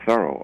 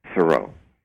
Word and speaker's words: Thoreau: I've been pronouncing his name Thoreau all the way through this, and the reason for that is that that's the way he pronounced his last name, but that's not the way his family pronounced the last name. The way his family pronounced it is Thoreau, the way I've heard you, David, saying it, and the way I used to always say it.